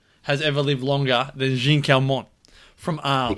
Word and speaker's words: Jeanne Calment: I've always pronounced it Jeannie Kellerman. That's the way, that's my Aussie pronunciation. Jeanne Calment.